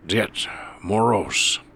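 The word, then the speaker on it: Ded Moroz: It's spelled D-E-D, yes? – Yes, sir. – It's pronounced Dead Moros. It means Grandfather Frost.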